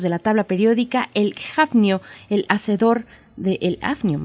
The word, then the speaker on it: Hafnio: Creo que sí se pronuncia Jafnio, que no debería, pero pues se, quedó la, pronunciación como si fuera inglés.